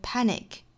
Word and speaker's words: panic: Mastery of panic， mastery 跟后面 of 连读，mastery of，相当于是在两个元音之间呢加了一个小小的 e 的发音，mastery of。后面的 panic，那我们昨天讲到 man，前天讲到了 had，里面的这个字母 a 呢都是发成一个类似于 r 的发音，这里面的 panic 也是一样的，